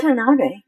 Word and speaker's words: Watanabe: It's Watanabe.